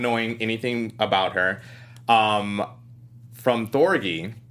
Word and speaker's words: Thorgy: Thorgy. Think of Orgy.